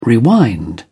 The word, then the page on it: rewind: British English